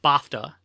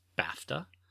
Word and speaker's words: BAFTA: and I pronounced it BAFTA, and apparently it's pronounced BAFTA.